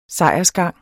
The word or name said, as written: sejrsgang